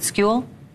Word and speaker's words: Skule: And did I get your name right? S-K-U-L-E. Is it School? – Yeah, it's School.